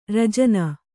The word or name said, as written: ರಾಜಾಣ